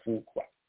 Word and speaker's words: Fuqua: Antoine Fuqua, if I'm pronouncing it right, because the U is like a W.